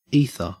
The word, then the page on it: ether: Australian English